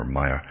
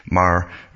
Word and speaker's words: Maher: I think it's Maher. I shouldn't pronounce it Maher or Meyer, but it's Bill Maher it's pronounced.